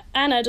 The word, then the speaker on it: Anna: Anna doesn't rhyme with banana in an English language.